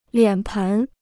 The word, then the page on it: 脸盆: liǎn pén